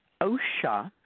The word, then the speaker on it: Osha: O-S-H-A, Osha. – O-S, sorry? – H-A, Osha. – O-S-H-A, okay. – Osha, just like yeah. – It sounds, right? Osha.